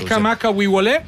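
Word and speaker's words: Israel Kamaka Wiwole (Israel Kamakawiwoʻole): Israel Kamaka Wiwole.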